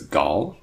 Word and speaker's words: Gal: But if you say her first name is Gal, like Hall or Call, then that's pretty close.